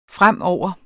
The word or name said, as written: fremover